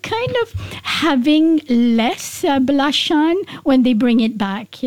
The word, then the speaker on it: Belacan: You know, the Hadrami forefathers called Belacan. – Belacan. – Belacan is like almost the Arabic pronunciation of Sambal Belacan.